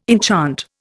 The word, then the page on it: enchant: Indian